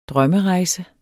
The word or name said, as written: drømmerejse